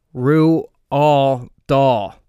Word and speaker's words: Roald Dahl: Since, like, fourth grade, I've been told that it's Roald Dahl. – Well, I looked it up. It's actually pronounced Roald Dahl. Roald Dahl is actually the pronunciation of his name.